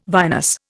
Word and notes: vinous: Indian

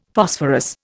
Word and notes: Phosphorus: Indian

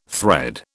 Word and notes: thread: British English

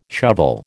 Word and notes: shovel: American English

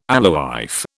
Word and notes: alewife: British English

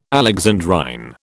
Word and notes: alexandrine: British English

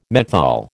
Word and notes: menthol: American English

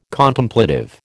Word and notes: Contemplative: American English

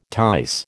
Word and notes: tice: American English

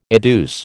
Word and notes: adduce: American English

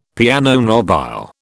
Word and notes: piano nobile: British English

